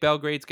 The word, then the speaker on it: Belgrade: I mean, if you've listened to this podcast long enough, you know that I can pronounce this team's name wrong on multiple occasions. I now know that it's Belgrade.